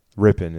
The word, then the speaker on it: Ripon: Ripon is how it's pronounced.